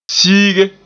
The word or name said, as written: siige